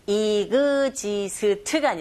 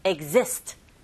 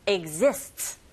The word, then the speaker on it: exist: 이그지스트가 아니라 exist, exist. 그런데 뒤에 s가 붙었으니까 exists 이렇게 발음해 주셔야 됩니다.